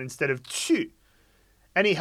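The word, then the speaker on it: chu (出): So there is a CH sound in Chinese, which is often associated with the character for to go out, which is pronounced chu.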